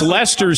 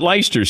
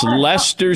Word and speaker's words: Leicester: So, and it's Leicester City. It's not Leicester City. It's Leicester City.